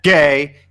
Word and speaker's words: Gay: And also, you had Matt Gay and Colby Wadman there. So, looking at Kenfield's team, wonderful, wonderful. And by the way, that was my hard G. That wasn't a homophobic thing.